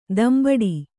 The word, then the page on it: ದಂಬಡಿ: dambaḍi